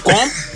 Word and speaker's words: gqom: Guam, like guam, innit? But you know when the South African man say what? – You might even click.